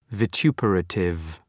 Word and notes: vituperative: vı’tju:pərətıv

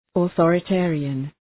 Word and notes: authoritarian: ə,ɵɔ:rə’teərıən

